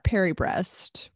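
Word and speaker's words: Paris-Brest: I looked up Perry breast, which is spelled like Paris -BREST.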